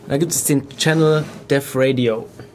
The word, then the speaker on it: Def Radio: Also Def Radio, es ist nicht das Todesradio, sondern... – Also nicht Death, sondern Def wie ja, Device.